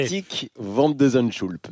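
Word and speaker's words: Botic van de Zandschulp: Botik van de Zandschulp.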